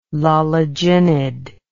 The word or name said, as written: loliginid